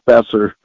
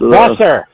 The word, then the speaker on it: Boeser: You're going to catch crap from any Minnesota fan for that pronunciation of Brock Boeser, a guy that. – Uh, Boeser! My apologies.